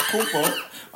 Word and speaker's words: Antetokounmpo: Kumpo. Antetokounmpo. – It's not Antetokounmpo. – It's Kumpo. All right. Copy. I'm going to look that up right now. Giannis. – Antetokounmpo.